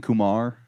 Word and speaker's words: Kumar: He said Harold and Kumar. – Harold and Kumar. – Harold and Kumar. – Cameron. – No, no, no. Oh, I said it wrong. I keep saying it wrong.